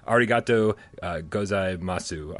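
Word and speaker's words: arigato gozaimasu: It's a bit, whoa, arigato gozai masu. I'm probably got that wrong. Hashtag higher pants for AJ. did I pronounce that totally incorrectly? – No, you actually nailed that.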